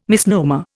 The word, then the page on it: misnomer: Indian